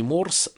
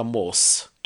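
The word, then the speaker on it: Morse: Ушел Блэкмор, пришел американский исполнитель на гитаре, Стив Морс но здесь вот произношение не Морс, наверное, а Мос, То же самое, как и некоторые произносят Deep Purple, но на самом деле правильно Deep Purple, потому что есть у них там такие словосочетания, где буква R, она пишется, но не произносится.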